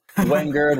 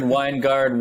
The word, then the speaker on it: Wengerd: Am I saying your last name right? – It's close enough. We get Wengerd, Wengerd, Wengerd. It's and sometimes I even pronounce it differently. It's all over the place, but it's fine.